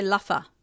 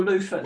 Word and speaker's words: luffa: So you say luffa. – Luffa or loofah. No, it's spelled L-U-F-F-A or L-O-O-F-A-H. – Okay. So either or. – You pronounce the two things differently.